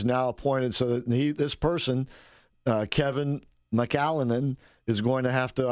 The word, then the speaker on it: McAleenan: Kevin McLennan. – McLennan. – I bet we're pronouncing it wrong. – McAllenan. Let me spell it for you. – McAllenan. – Yeah, there you go. McAllenan. McAllenan or something to that effect.